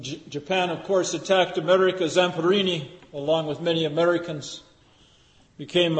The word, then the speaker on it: Zamperini: I know some of you, I was thinking of this when I read the story of Louis Zamperini, that's how you pronounce it.